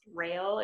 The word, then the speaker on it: Rael: There's a new brand that's, I think it's pronounced Rael. It's R-A-E-L.